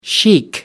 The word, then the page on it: sheik: British English